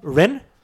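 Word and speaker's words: Wren: Ren? – Ja, e W-R-E-N.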